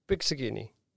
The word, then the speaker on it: Pizzichini: Yeah, the right pronunciation in Italian is pizzichini.